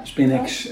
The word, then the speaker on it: Spinhex: Daar stond dat je van 1992 tot 2013 eigenaar en managing director van de drukkerij spreek je het goed uit, Spinhex en industrie? Ja, ja Spinhex oh.